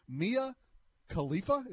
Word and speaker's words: Mia Khalifa: direct messages to adult actress actress star Mia Khalifa. Is that right? – Uh, that is correct pronunciation of it, yes.